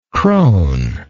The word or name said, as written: crone